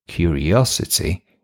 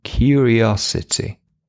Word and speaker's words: curiosity: Let's learn the pronunciation of this word because it's a little bit tricky. Curiosity. Okay, let's start with the two last syllables. It's osity. Three syllables, actually. Osity. Osity. Now, let's add the beginning. Curiosity.